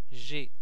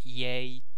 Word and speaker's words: j'ai: J'ai, c'est bien j'ai, amis hispanophones. Ce n'est pas yay, jay, non, j'ai.